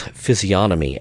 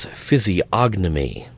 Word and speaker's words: physiognomy: Collier pronounces physiognomy as physiognomy. The G is supposed to be silent, at least any time I have ever heard anyone else pronounce that word.